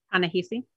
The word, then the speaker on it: Ta-Nehisi: Like Ta-Nehisi, I'm probably saying the name wrong. Ta-Nehisi Coates once wrote like- Ta-Nehisi. – Thank you.